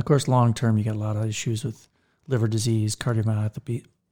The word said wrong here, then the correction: cardiomyopathy: Did I just say that wrong? – Yep. – Cardiomyopathies and, you know, stroke and such, pancreatitis.